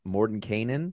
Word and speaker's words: Mordenkainen: So Doric infiltrates Forge's castle by animal shapeshifting and finds the vault has magical defenses from Mordenkainen.